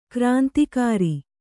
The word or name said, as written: ಗ್ರಂಥಕಾರ